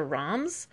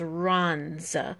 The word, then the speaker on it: Reims: So it's said, or it's spelled R-E-I-M-S, and so it looks like Reims or Roms, but I'm pretty sure it's Rons. I did check with Google, so just FYI.